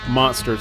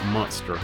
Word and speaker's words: Munster: Munster. So George IV pronounces it Munster, like M-U-N-S-T-E-R.